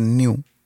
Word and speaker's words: nu: Pero bueno, que, le iban a poner new, que una letra es NU, se, se pronuncia new.